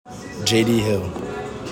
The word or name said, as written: JD HILL